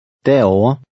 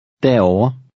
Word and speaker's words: derovre: The V is silent. – Derover. Derover.